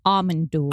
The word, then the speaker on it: Amon Düül: Actually, the correct pronunciation is Amon Düül. – Oh, fuck. Okay, yeah. From now on, Amon Düül.